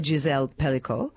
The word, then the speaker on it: Gisèle Pelicot: I'm just wanting to know, you actually dedicated it to to Gisèle Pelicot.